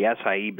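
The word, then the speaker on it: açaí: I spell it A-C-A-I berry. How do I pronounce that? – It's pronounced the acai berry, believe it or not.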